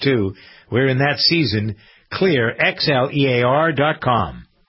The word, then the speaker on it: Xlear: Pronounced Clear, but spelled X-L-E-A-R.